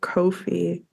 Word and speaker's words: Ko-fi: I looked up how to say it online and it said it rhymes with no fee. So Ko-fi.